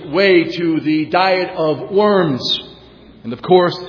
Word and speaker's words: Worms: and the word worms, which, by the way, is correctly pronounced Worms, is a city in Germany.